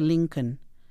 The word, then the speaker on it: Lincoln: Presta mucha atención al deletreo del apellido de Lincoln, que aunque no se escucha la L en su apellido, sí se escribe.